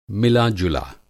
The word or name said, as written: मिला-जुला